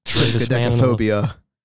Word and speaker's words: triskaidekaphobia: but the one thing I thought of triskaidekaphobia.